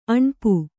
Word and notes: ಅಣಿಪೆ: kannada

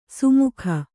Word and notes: ಸುಮುಖ: sumukha

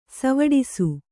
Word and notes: ಸೈವಿಡಿಸು: saiviḍisu

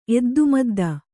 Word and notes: ಎದ್ದುಮದ್ದ: eddumadda

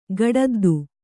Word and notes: ಗಡದ್ದು: gaḍaddu

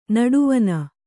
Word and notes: ನಡುವನೆ: naḍuvane

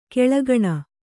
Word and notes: ಕಾಳ್ಕೋಣ: kāḷkōṇa; Kannada

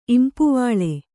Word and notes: ಇಂಪುವಾಳೆ: kannada

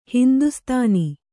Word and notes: ಹಿಂದೂಸ್ಥಾನ: Kannada

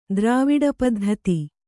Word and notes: ದ್ರಾವಿಡಪದ್ಧತಿ: drāviḍa paddhati; Kannada

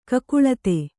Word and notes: ಕಕಲಾತಿ: kakalāti

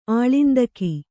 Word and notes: ಆಳಿಂದಕಿ: āḷindaki; dial.

